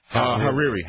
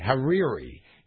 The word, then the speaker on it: Hariri: Hariri. – Hariri.